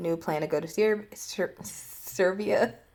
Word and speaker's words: Serbia: Brings up her traveling to Serbia. Serbia? Did I say it right? – Yep.